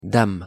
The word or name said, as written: dame